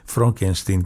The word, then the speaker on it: Frankenstein: θέλει να προφέρεται Φρόγκενστιν και όχι Φραγκενστάιν,